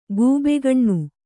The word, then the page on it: ಕಬ್ಬಗಣ್: kabbagaṇ; Kannada